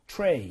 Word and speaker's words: tray: 트레이가 아니라 어떻게 써요? – Tray. – 아하. 이것도 결국 electronic이랑 같은 맥락이죠. 네. TR 다음에 모음이 나오면 치읓 비슷하게 발음이 되는 거.